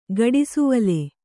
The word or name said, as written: ಗಡಿಸುವಲೆ